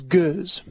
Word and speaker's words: gueuze: Then it becomes what's known as gueuze. And just for pronunciation's sake, that's G-U-E-U-Z-E.